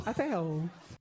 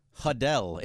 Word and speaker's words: Adele: That's how we all say it. – Hadel. It's not Adele. – No, that's what she's saying. – She's not saying there's a silent H. There's no silent.